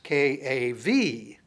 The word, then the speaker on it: kabod: And kabod is the word that is used. It'd probably be spelled K-A-B-A-D if you were to look at transliteration. It's actually pronounced K-A-V-A-D. B's are usually pronounced that way.